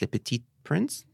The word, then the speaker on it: Le Petit Prince: Le Petit Prince.